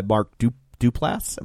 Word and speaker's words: Mark Duplass: She has a brother played by Mark Duplass. Am I saying that right? – Yes.